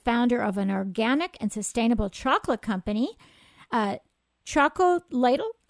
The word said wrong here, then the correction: Chocolatl: I'm going to have to ask her how she pronounces it. And it, Chocolatl is committed. Chocolatl, thank you, is committed.